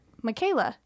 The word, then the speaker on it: Michaela: And he goes, oh, well, what's the proper way to say your name? And I was like, Michaela.